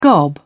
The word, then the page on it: gob: British English